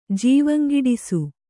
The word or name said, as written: ಜವಂಗಿಡಿಸು